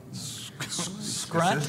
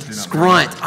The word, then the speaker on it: Scrunt: So like, what's the I can't pronounce it, Scruff? – Scrunt. – Scrunt. I always want to say Scruff. So Scrunt. Every time Scrunt poked me with the dagger, it actually hurts, and I just pretend it doesn't.